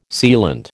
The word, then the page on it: sealant: American English